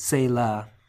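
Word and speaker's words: tsela: We'll have to brutalize it, but the the way we usually say it in English is Selah.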